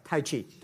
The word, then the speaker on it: Tai Chi: Am I saying that right? A trainer. – Trainer. – Tai Chi? – Tai Chi. – Tai Chi. – Tai Chi, yeah.